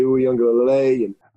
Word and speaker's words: Uiagalelei: DJ Uiagalelei.